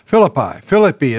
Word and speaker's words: Philippi: Philippi is the proper pronunciation, I understand, but I'm afraid I can't resist Americanizing it, so we'll do the best we can.